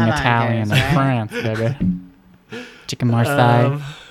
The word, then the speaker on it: Marseille: Marseille. – Very bad. – Marseille. My history teacher always said, don't pronounce that Marcellus, Marcelli. – Marcellus? – Because you'll sound like a loser.